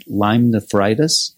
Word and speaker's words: Lyme nephritis: But it can lead to something called Lyme nephritis. Did I say that right? – Yeah, absolutely. Just kidney disease associated with those bacteria.